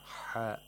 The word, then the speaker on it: حاء: حاء has بحة. حاء is pronounced from the top of the throat by tightening the throat, the top part of the throat.